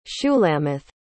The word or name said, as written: shulamith